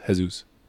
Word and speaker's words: Jesus: You can search up just by my actual name, Jesus Arellano, or damn, I don't even know my own username. It's like double H, Jesus, but it's like it's just pronounced Jesus.